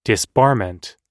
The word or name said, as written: disbarment